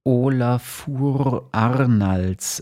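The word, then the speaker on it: Ólafur Arnalds: wie wird er ausgesprochen nochmal, um das richtig zu machen? – Olafur Arnalds.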